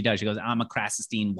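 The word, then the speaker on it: Krassenstein: The Krasenstein brothers? – Krasenstein. – Thank you.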